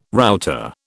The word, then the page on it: router: British English